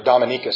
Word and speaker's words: Dominica: A number of years ago, a preacher was doing some famine relief in the country of Dominica, or Dominica, sometimes it's pronounced, down in the Caribbean.